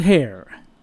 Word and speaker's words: Hare: And you can remember that because Hare rhymes with bear-a-tone. They made their first record for Brunswick, and they recorded for 16 different phonograph companies. Here are Jones and Hare.